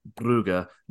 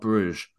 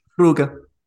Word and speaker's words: Brugge: We just need to know how to say their team's names, like Brugge, not Bruges. – Brugge.